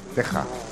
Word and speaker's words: Teja: T-E-J-A. – Teja. – Sí, en alemán sería Teja.